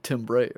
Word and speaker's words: timbre: Because it's spelled like timber, but I think it's... – Well, it's spelled like timbre, right? – Timbre, yeah, timbre.